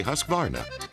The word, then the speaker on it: Husqvarna: So I teach you now how to say Husqvarna. You say husk like corn and varn like varnish, without the ish, and nah.